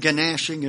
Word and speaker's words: gnashing: There shall be weeping and gnashing of teeth. It's actually silent G, but I'm making a emphasis here.